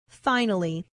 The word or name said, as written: finally